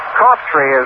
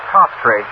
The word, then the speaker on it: Coughtry: Cawtry is the way fellow pronounces his name. It's spelled C-O-U-G-H-T-R-Y, but he pronounces it Cofftree.